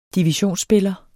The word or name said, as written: divisionsspiller